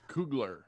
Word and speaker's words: Coogler: I'm going to mispronounce his name, Ryan Coogler. I believe I pronounced that right. – Coogler.